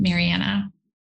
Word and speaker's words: Marianna: So welcome, Marianna.